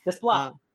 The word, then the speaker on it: Desplat: I don't know how to pronounce his last name. – Desplat.